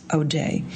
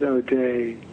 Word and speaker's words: O'Dea: A lot of Americans do pronounce it O'Day, but if you go to Ireland, they pronounce it O.D. – Very good. – P-E-A and D-E-A and S-E-A.